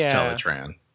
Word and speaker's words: Teletran: Yeah, it is Teletran.